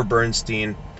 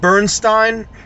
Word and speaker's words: Bernstein: And always make sure you're saying his name correctly. It's never Bernstein. Leonard Bernstein conducted more groups than we can possibly even name.